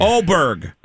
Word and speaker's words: Åberg: Åberg.